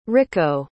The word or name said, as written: Ricco